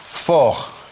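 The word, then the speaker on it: fort: Просто, да, слово «сильный» – «фор». В женском роде «форт». «Фор» – это «сильный».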